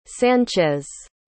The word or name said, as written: sanchez